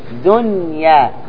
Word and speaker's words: دنيا: كيف تنطق دنيا؟ نون واضحة مظهرة بدون غنة، بدون غنة.